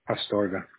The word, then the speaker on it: Astorga: Astorga. A-S-T-O-R-G-A. – A-S-T-O-R-G-A, right? – Yes.